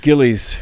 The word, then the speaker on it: Gillies: Give me the proper pronunciation. – Gillies. Rhymes with sillies, billies, or fillies.